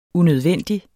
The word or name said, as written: unødvendig